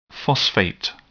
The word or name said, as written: phosphate